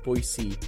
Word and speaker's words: Boise: It is Boise State. There's my PSA for the day. Little soft S's all around.